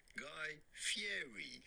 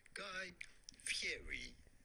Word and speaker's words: Guy Fieri: We are looking at how to pronounce Guy Fieri. – No! – His full name, no. – Guy Ramsay Fieri. Guy Fieri.